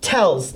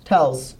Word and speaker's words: Tellz: Tells. T-E-L-L-Z. – Can you spell that for me, Alan's last name? – T-E-L-L-Z. Tells.